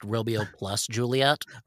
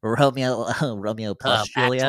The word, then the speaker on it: Romeo + Juliet: So Baz Luhrmann's rendition of the story, which he actually entitled in the film William Shakespeare's Romeo and Juliet, was released I believe it's pronounced Romeo plus Juliet. – Romeo plus Juliet. Actually.